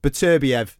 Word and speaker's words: Beterbiev: you're going to love this, Gareth, his pronunciation, Beterbiev. There you go, lad, you can have that. Normally I say... – Wow, that is almost cultural corner. – Yeah, normally I say Beterbiev. It's a bit northern for Gareth, you don't like that.